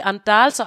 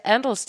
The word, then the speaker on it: Andals: De hedder nemlig Andals, og alle dem, der sidder og snakker om det, siger Andals, de udtaler det forkert.